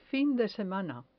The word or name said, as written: fin de semana